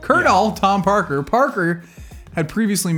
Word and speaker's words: Colonel: That would be pronounced Colonel, but why do they do that? Why can't they just why does this happen to me? Why can't they just write Colonel? It says Colonel.